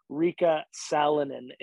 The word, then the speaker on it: Riikka Salonen: And the Finnish superstar you were talking about is Riikka Salonen, is I believe how her name is pronounced properly.